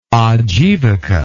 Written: Ajivika

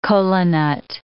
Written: kola nut

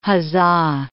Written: huzza

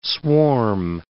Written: swarm

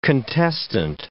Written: contestant

